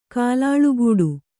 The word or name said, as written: ಕುಲುಲುಗುಟ್ಟು